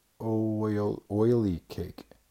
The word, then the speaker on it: Olykoek: Oily cake. So Olykoek is pronounced oily cake.